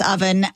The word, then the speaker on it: Ovven: His name's Owen, but it's spelled O-V-V-E-N. It's pronounced Oven.